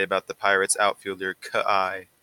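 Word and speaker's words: Ka'ai: And it is, I looked at the pronunciation for this guy's name. It was Kai.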